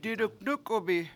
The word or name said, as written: diduNUkubi7